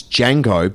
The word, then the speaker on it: Django: Did you say Django? – No. It's Django. But the D is silent.